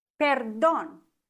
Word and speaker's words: Perdón: Perdón. Mit Betonung auf dem Don, weil hier auch ein Akzent drauf ist, auf dem O.